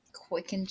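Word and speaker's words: Qican: So it's Q-I-C-A-N, and then Jones is normal. That's love. – Quicken Jones. Oh, that's so cute. I love him. Oh, his name is so cute. I adore him. I also like rabbit people. Victor approaches Quicken and places a hand on the table to, like, establish himself in the shot.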